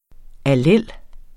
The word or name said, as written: allel